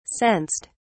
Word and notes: sensed: British English

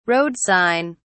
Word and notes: road sign: British English

